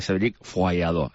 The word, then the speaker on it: Cédric Follador: Pues Cédric Follador, exactamente, ¿eh? Pero se produce Cédric Follador, que claro. He estado buscando la pronunciación francesa.